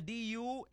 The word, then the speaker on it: Dumangas: Halin siya sa Cebu, gin pamangkot niya, niya din kasubong, aba niya, ari ko sa Sitio Dumangas, ang Dumangas niya, Dumanggas, bilang lugar, nga bastante sa pagkaon.